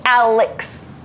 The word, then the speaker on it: Alex: Sometimes people think my name is Alice, and I have to be like, Alex. There's an X in this name.